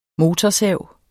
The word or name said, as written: motorsav